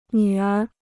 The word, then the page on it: 女儿: nǚ ér